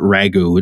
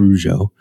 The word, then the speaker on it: Rougeau: And no, it's not Ragu, it's Rougeau.